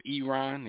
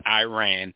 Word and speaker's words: Iran: on how to pronounce the country of Iran. They keep saying it's not Iran, it's Iran.